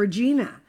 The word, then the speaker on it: Regina: Regina. And the person said to me, oh, no, it's Regina rhymes with vagina.